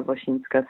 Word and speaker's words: Wosinska: And then I do have a, um, Instagram account for my photo stuff called Magda Wosinska Studio.